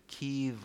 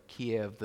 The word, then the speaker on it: Kyiv: And one of the missionaries we support, Brother Daniel Holmes, is just anguishing over his people in, they pronounce it Kyiv or Kiev, the capital city of Ukraine.